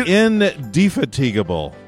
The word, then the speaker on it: indefatigable: My name is Johnny Craig, and I am sitting across the digital desk with the one and only, the infatigable, the greatest mentor a guy could ask for. – Johnny, if you're going to give me a great word like that, you got to pronounce it right. The indefatigable.